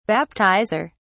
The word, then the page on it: baptizer: British English